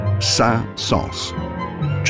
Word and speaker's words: Saint-Saëns: It is pronounced Saint-Saëns.